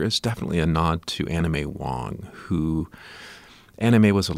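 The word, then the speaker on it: Anna May Wong: Anna Mae Wong. – Anna Mae Wong, yeah.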